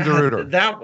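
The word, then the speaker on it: DeRuyter: Tim DeRuiter. – That one's going to... screw me up every time. DeRuiter. That Y should be pronounced. Otherwise, remove it.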